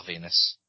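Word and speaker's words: Venis: Even though the only other Val I know is Val Venus, which rhymes with penis.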